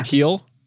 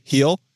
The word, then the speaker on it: Gil: Gil. Soft G. Gil.